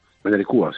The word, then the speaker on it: Mañaricua: Mañaricua, sí, es correcto. – Mañaricua, perdón, con acento en la U, lo he dicho mal entonces.